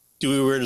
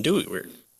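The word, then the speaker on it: Dooyeweerd: But his seminal ideas were developed into full-fledged philosophies by others, notably Herman Dewey Weird. – Right. And so 1894 to 1977 is when I think you pronounce it like Dewey-Weird. The W is pronounced as a V, I think, in German.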